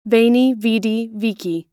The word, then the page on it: veni vidi vici: American English